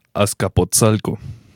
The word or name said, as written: Atzcapozalco